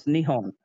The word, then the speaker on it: Nihon: And how we ended up calling, which I think they're actually, so if I'm remembering correctly, it is it Nippon or is it Nihon? I think it's Nippon, right? – Mm. – No, it's Nihon. It's so it's Nihon is how they actually pronounce their country,